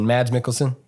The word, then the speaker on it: Mads Mikkelsen: How do you pronounce his name? – Mikkelsen, Mads Mikkelsen. – Mads Mikkelsen. – Yeah. – Okay. Yeah. – That's how I've heard him actually say it.